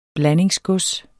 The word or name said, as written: BLANDINGSGODS